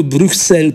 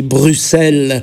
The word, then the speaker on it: Bruxelles: Eu m-aș mulțumi însă și cu un simplu Bruxelles pronunțat corect. Nu trebuie neapărat să graseiez ca francezii Bruxelles și nici măcar să fluiere acel U cu buzele țuguiate, U, dar măcar accentul să cadă corect pe a doua silabă, adică nu Bruxelles, ci Bruxelles.